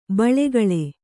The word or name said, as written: ಬಲಗಾಲ್